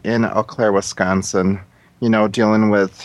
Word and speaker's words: Eau Claire: Eau Claire. – Eau Claire. – Eau Claire.